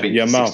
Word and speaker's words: Yamal: I think it's Yamal. – Yeah, Yamal, 16-year-old.